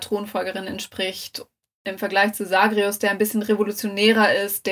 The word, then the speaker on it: Zagreus: Ja, Hades hat den Thron leer zurückgelassen und daraus ist ein Machtkampf entstanden zwischen den Königskindern Melinor und Sagreus, die sich, muss mich dran gewöhnen, Sagreus zu sagen und nicht ihn immer Zagreus zu nennen, wie man das so in der englischen Aussprache kennt.